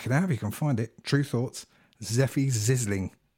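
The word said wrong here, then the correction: Sefi Zisling: Matt rightly points out I've been mispronouncing that. Seffy Zizzling. That's what it is.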